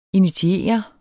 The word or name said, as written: initiere